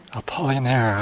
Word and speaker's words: Apollinaire: Guayam Apollinaire. Apollinaire, ah, thank you.